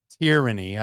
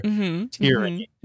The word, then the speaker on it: tyranny: Struggles with pronouncing tyranny. I have to remember tear, like a teardrop from my eye over that extreme authoritarianism that's possible under tyranny. – Mm-hmm. – Not to say tyranny.